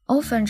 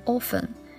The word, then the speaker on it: often: Often 这个词的读法有两种。一种是像抖森这样读成 often，一种是像另一版里面的读成 often，这两种都是对的，排名不分先后。